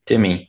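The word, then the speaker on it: Timme: Is that how you say his name? Timmy? – Timmy, yep.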